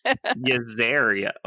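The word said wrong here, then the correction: Jezrien: Is it Yezaria? – Yezrian. – You know I'm not going to pronounce names right.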